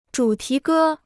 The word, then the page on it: 主题歌: zhǔ tí gē